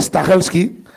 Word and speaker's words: Stahelski: Chad Stahelski.